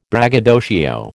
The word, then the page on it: braggadocio: American English